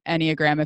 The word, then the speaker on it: Enneagram: The Enneagram, it's weirdly spelled for how it's pronounced. It's Enneagram, Enneagram, if any of you want to look it up.